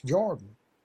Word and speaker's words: Jourdan: I crossed over the River Jordan, J-O-U-R-D-A-N, which is pronounced Jordan.